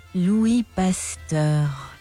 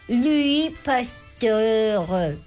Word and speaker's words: Louis Pasteur: Louis Pasteur. – Nah, pues en español. ¿En francés? ¿En francés, por favor? – Louis Pasteur. – Louis Pasteur. Ya, ahí lo dije bien, ¿viste?